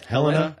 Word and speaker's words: Helena: so this 27-year-old shooter told authorities that he was putting up targets outside of Helena I hope I'm saying that right. – Yeah, Helena, Helena, Helena, Helena. Yeah. Helena.